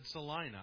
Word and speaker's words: Salina: And I've said Salina.